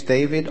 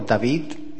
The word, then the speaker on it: David: My name is David, or David, if you pronounce it in Spanish.